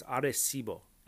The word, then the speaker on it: Arecibo: I'm trying to say it as I imagined you'd say it in Spanish. Arecibo. Arecibo Observatory. Arecibo is a word that sounds like you should try to pronounce correctly, like Arecibo. You shouldn't say Arecibo or Arecibo.